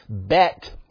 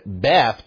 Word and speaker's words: beth: Now, in the scriptures, the majority of time in which you see the bet, or as we pronounce it, beth, prepositional usage, they're most often related to a location.